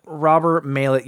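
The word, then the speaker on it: Maillet: How do you say his name? Robert Mallet. – Robert Mallet. Yeah.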